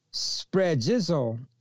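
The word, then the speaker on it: sphragizo: Okay, now we discuss in Revelation chapter 7, verses 3 and 4, how the word for seal in Greek is spregizo, spread, spre, that word is kind of like a tongue twister.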